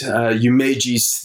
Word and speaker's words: Yumeji's: From In the Mood for Love, and it's Umeji's theme. Umeji's theme, and that's spelled Y-U-M-E-J-I-S.